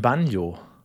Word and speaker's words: Banjo: Oh ja, und sogar richtig ausgesprochen, nicht schlecht. – Wieso? – Ja, viele haben den Riegel so schon früher Banjo genannt. – Wirklich? – Ja. – Ach, nee, das hätte ich immer deutsch gesagt, ein Banjo.